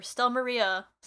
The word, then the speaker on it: Stelmaria: Stelmari is giving him these, or Stelmaria, sorry.